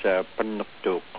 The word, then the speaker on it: Pangnirtung: As Amanda said, I'm from Pangnirtung, Nunavut, and in Pangnirtung we call in Inuktitut it's actually pronounced Pannuktuk in our own language in Inuktitut.